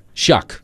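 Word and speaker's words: Shough: Shuck. – Okay. – Rhymes with duck and buck and luck and you know what else.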